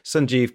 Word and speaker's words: Sanjeev: comedian and writer Sanjeev Kohli. How are you doing? – I'm good, and congratulations on the correct pronunciation of my first name,